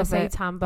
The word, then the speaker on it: seitan: Especially when you've got, I always say it wrong, is it seitan? – Seitan, yeah.